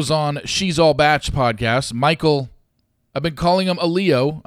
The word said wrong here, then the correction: Allio: I think everybody has since the beginning of time, since he showed up on Katie's season. Find out his last name is pronounced Alio.